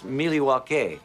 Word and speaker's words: Milwaukee: Actually, it's pronounced Milwaukee, which is Algonquin for the good land.